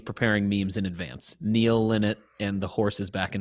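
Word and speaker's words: Lynott: How do you pronounce it? I think it's Linnet. – No, it's Linnet.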